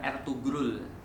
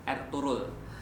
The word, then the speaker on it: Ertuğrul: Osman ini adalah anak daripada Erturul tulisannya Ertugrul tapi orang-orang Turki itu punya G yang G-nya itu uh, G-nya itu agak-agak rahasia nah, jadi bacanya bukan Ertugrul Erturul bukan